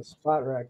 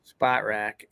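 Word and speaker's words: Spotrac: But, you know, looking at the Vikings situation right now, they have $28.9 million in cap space, and according to Spot, how do you say that name? Spot Track? I don't know. – Spot Rack, yeah. – Spot Rack.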